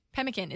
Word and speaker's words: Pemmican: Pemmican. I don't know how you say it. – Pemmican.